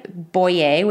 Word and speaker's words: Boyer: It's my first name, Julie, C, M like Mary, and my last name, Boyer, which is spelled B-O-Y-E-R.